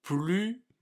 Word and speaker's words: plus: plus marcher. Vous avez remarqué que cette fois, on prononce plus. On ne dit pas le S. Mais ça s'écrit toujours P-L-U-S.